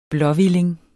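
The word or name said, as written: blåhvilling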